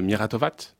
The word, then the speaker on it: Miratovac: Donc sur cette troisième étape du côté de la Serbie, on est à l'entrée du village de Miratovac. C'est comme ça qu'on prononce. Il